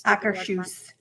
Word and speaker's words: Akershus: Akershus.